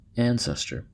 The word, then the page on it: ancestor: American English